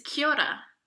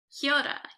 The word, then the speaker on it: kia ora: Kia ora. Yes. – Did I say that right? Wait, say it again. Kia ora. Kia ora. – Did yeah. I say that? Yeah. Yeah.